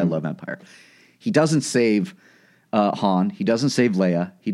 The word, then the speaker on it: Han: Han.